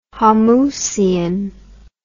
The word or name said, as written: homousian